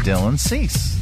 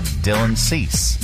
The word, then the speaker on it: Dylan Cease: Dylan Cease. – I got it right. – Dylan Cease. – I said Dylan Cease.